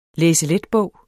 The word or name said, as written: læseletbog